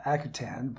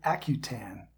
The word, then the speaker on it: Akutan: You know, I've mispronounced it myself for many years. I pronounced it Akutan, but I know a young woman who was a fisheries biologist who moved to Onalaska for a while. I'm probably mispronouncing that as well, and she pronounced it Akutan.